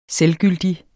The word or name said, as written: selvgyldig